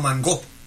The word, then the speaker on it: mango: Nosotros en Puerto Rico le decimos mango. Le ponemos el acento en la O. Y lo ponemos como un nombre agudo, que lleva acento. Aleluya. Y si no era acento ortográfico, era prosódico. El acento prosódico es donde lleva la fuerza de pronunciación, pero no se acentúa.